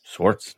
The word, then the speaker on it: Swarts: Swarts.